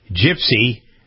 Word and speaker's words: GPSCY: Yale University senior pushing PC to the limit, asking classmates to stop referring to their campus student center, known by its acronym GPSCY, Gypsy, Graduate and Professional Student Center at Yale, because they pronounce it that way, Gypsy.